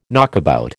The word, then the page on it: knockabout: American English